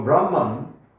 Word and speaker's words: Brahma: In Brahma, the long A at the end is very important.